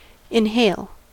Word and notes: inhale: American English

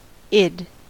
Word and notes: id: American English

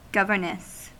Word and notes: governess: American English

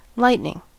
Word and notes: lightning: American English

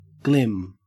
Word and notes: glim: Australian English

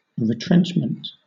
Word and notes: retrenchment: British English

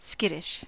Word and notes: skittish: American English

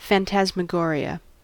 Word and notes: phantasmagoria: American English

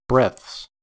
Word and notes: breaths: American English

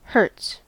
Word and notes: hertz: American English

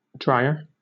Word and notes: drier: British English

